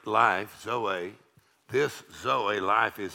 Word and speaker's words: zoe: We know the Greek word there is the word Z-O-E, which we pronounce Zoe.